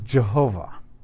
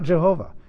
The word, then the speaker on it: Jehovah: And so because there are no Ys in German, and there are Js, and the Va, the W is pronounced with a Va in Hebrew and German, it becomes Jehovah.